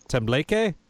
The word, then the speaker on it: tembleque: I'll take a stab at the pronunciation, tembleque. Am I close? – Perfect. Yeah.